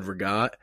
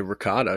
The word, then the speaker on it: ricotta: Okay, we're going to get through the fact that you just said regat. – Isn't that how you say it? – I mean, that's not how I would say it. – How do you say it? – I would say ricotta because I'm from America. And that's how you read it in English.